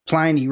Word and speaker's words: Pliny: It, not only can you order a tiny Pliny, but Pliny rhymes with piney, which is that big hop forward flavor that I think a lot of people encountered for the first time in that era.